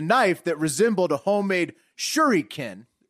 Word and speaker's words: shuriken: It's a shuriken.